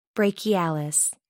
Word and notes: brachialis: American English

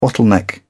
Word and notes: bottleneck: British English

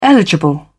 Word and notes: eligible: British English

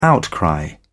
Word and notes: outcry: British English

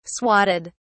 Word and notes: swatted: British English

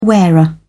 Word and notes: wearer: British English

